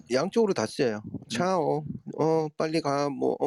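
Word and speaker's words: ciao: 저그 벨라 치오가 아니고 차오.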